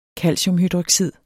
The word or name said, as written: calciumhydroxid